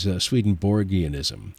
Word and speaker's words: Swedenborgianism: The first one I'd like to talk about is Swedenborgianism.